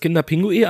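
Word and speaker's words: Kinder Pingui: Lange habe ich es falsch ausgesprochen, wusste ich nicht. Ich dachte auch immer, es heißt Kinderpinguin, aber eigentlich mhm. logisch, dass man ja kein Pinguin essen kann.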